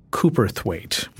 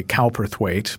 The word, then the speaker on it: Cowperthwaite: So tell me about who John Cooperthwaite was. That's the British pronunciation. Americans might say Cowperthwaite, but who was he?